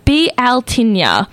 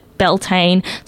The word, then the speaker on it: Bealtaine: So this week we have Beltane, which is traditionally pronounced Bealtaine, and I had to look this up as it is a pagan holiday, and it is apparently a well-known fact that pagan holidays are hard to pronounce, but I have been informed that it is not offensive to call it Beltane, so that is probably what we'll be referring to our holy day of the week this week.